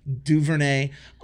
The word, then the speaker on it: DuVernay: Ava DuVernay um,